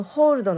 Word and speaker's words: hold: この hold のえー、発音なんですけれども、えー、ゆっくり言うと hold hold になります。 o のところ